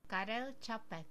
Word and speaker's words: Karel Čapek: Capic, Capek, but like with a an inverted caret above the C. – Gotcha. And it's not in the wiki. There's no pronunciation. It's here. Listen. – Karel Čapek. – Čapek. – Čapek. Got it.